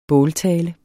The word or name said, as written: båltale